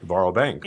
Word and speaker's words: Varo Bank: So in a moment, I'm going to have you talk about Varo Bank. Do I have that pronounced right? – That is correct, Varo Bank.